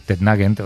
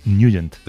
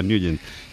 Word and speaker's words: Nugent: Hombre, siempre lo hemos llamado en el barrio Tednagent o Tednagent. – Claro, yo era de Tednagent. – Pero la pronunciación correcta es Nugent. – Te fuiste a Washington y descubriste que era Nugent.